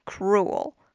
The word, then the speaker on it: cruel: Cruel. Cruel 这个词是残酷的意思，注意这个词的发音也是稍微有点小难度的。最后这个 cruel, 这个结尾的地方有一个舌边音 L, 注意要抵到我们的上齿龈舌尖 cruel.